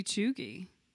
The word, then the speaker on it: cheugy: Have you heard the term, I don't know if I'm pronouncing it right, chuggy? – I don't know. Describe it. – It's, well, first of all, it's spelt C-H-E-U-G-Y. – C-H? – E-U-G-Y. Chuggy.